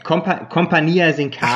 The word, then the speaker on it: Compagnia Sincara: So, ich habe versucht, die Betonung dieses Mal richtig hinzukriegen. – Genau, Compagnia Sincara. – Ach ja, Compagnia Sincara. So. – Genau. So ist, oder so sprechen wir es aus.